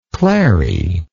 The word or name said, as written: clary